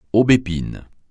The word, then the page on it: aubépine: francais